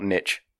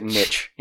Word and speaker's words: niche: and it's niche, not niche. Fuck you. Everyone in America pronounces it niche.